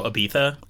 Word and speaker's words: Ibiza: Totally sounds like how the British pronounce Ibiza. They go Ibiza. – Ibiza. – But we say Ibiza.